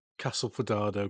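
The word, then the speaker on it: Castelfidardo: I'm glad you said Castel Fidardo, because I wouldn't I've been miles off with my pronunciation of that.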